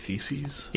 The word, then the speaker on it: theses: Theses about, is that how I'd pronounce it? Theses? – Yes. Multiple theses. – Multiple theses. Of thesis. Yeah.